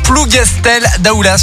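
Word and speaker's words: Plougastel-Daoulas: Dimanche dernier, près de Brest, dans le Finistère, la commune de Plougastel d'Aoulas, je ne sais pas si je le dis bien. – Je pense que si, ouais. – Je sais pas si le S est muet ou pas.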